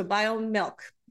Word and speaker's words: Biomilq: It's formulated by a company called BioMilk, and that's spelled B-I-O-M-I-L-Q, so BioMilk.